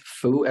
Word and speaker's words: Foo: Mine is the same username everywhere, James L, as in Lewis, Foo, F-O-O.